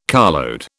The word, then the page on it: carload: British English